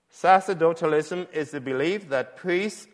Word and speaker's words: sacerdotalism: Sacerdotalism, that's how it's pronounced.